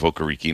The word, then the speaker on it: Okereke: His first half of his career, he was Bobby Okereke. And then he decided, you know, it was not pronounced that way. It was he called himself Okereke, and it was going to be Okereke.